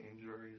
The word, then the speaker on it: Amesbury: Did I pronounce that correctly? Amesbury? – Yes.